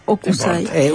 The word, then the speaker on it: Hokusai: Sí, bueno, acá traje en un libro de estampas dedicado a Hokusai, no sé si es Hokusai o Hokusai. – Un oyente dice que hay que pronunciar esa H. – Hokusai.